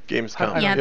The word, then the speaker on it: Gamescom: Blizzard's Gamescom? Is that how we pronounce it? Gamescom? – Gamescom. – It's com. – With an M at the end, not an N.